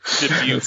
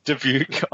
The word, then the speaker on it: Dubuque: how do you say that town again? Dubuque? – Dubuque. Sounds right. – Dubuque, Iowa, which he found was even...